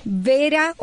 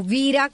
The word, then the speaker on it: Vera: Vera o Vira, como se debe pronunciar, Vira Jaber, de 33 años,